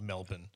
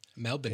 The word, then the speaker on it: Melbourne: Yeah, it's spelled Melbourne like O-U-R-N-E, but just pretend like there's no O-U. And it's just like E-N, like Melbourne. – We just say Melbourne. – Melbourne.